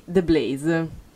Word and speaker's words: The Blaze: Quindi, allora, abbiamo ascoltato Places dei The Blaze.